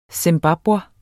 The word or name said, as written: zimbabwer